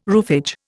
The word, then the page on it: roofage: Indian